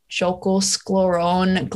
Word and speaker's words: Jökulsárlón: There's this place called the like Diamond Beach, which is next to like, I think it's pronounced like Jökulsárlón Glacier Lagoon.